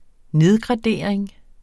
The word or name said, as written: nedgradering